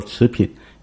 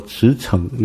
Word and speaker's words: 驰骋: 嗯，我有一次在 LA 在洛杉矶服侍他们的特会，然后讲到的时候提到了一个词，我很少提到那个词，所以一直过了那么多年才有人纠正我，就是我把它读成持品，结果会后有一位姊妹他也是很温和的来提醒我，他说那个字不读作持品，应该读作驰骋，你知道什么字吗？一个马在一个像聘金的聘的右边那个字，那读作骋不读作品。